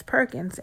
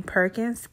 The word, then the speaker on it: Perkins: Perkins. And I spell it A-S-H-L-E-A. My middle initial is S, last name Perkins, P-E-R-K-I-N-S.